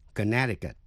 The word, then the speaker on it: Connecticut: Nhưng tên tiểu bang Connecticut, âm C trong âm tiết N-E-C, câm, không đọc. Quý vị nghe lại, Connecticut. Xin nghe và so sánh, connection, Connecticut.